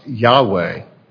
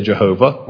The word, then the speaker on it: Yahweh: which is probably a mistaken pronunciation. It's kind of come into use. We have it in our hymns. But that is probably a word that never existed in biblical times. We use it. We think of it as a name for God. It's become customary in that way. But the word is more likely to have been pronounced Yahweh.